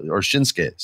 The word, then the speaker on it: Shinsuke: So, you know, I hope, like Shinsuke, man, Shinsuke, sorry for the extra syllable there.